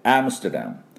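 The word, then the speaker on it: Amsterdam: ですから 3 音でアムステルダムと発音して、これはおみゃーの音がポイントです。あとアクセントね、最初アムステルダム、ここにももう一個、第二アクセントがありますので、ね、同じこのおみゃーのポイントです。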